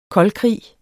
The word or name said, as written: koldkrig